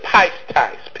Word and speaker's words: pistis: If you look at that word believe used in this verse, it is taken from the root word, and I pronounce it pistis, P-I-S-T-I-S, which is the word where we get the word faith from.